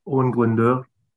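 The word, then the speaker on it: Owain Glyndŵr: Owen Glyndwr.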